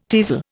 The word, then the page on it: teasel: Indian